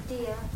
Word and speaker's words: Diya: Thea. – Thea? – Dia. – Dia. How do you spell that? – D-I-Y-A. – D-I-Y-A.